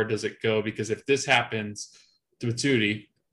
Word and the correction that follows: Matuidi: is that how you say it? Matuidi. Matuidi.